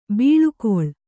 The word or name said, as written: ಬಳಿಗೊಳ್